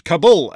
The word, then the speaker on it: Kabul: Because after enduring the pronunciation of Kabul for the past 20 years, we're now going back to the way it should be pronounced. Kabul, a special greetings to all of you in Kabul.